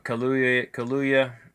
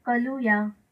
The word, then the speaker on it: Kaluuya: Get Out stars Daniel Kaluuya. I'm sorry, I messed up that last name. Kaluuya pronunciation. – Here we go. Daniel Kaluuya. – Okay. That's how you say Daniel that? Daniel Kaluuya. Oh, all right.